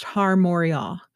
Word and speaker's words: Har Moriah: Mount Moriah in Hebrew is pronounced Har Moriah.